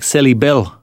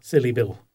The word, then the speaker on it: Silibil: That syllable is spelled S-I-L-I-B-I-L. – So like Silly Bill. – Silly Bill, yes.